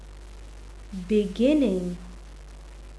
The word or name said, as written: beginning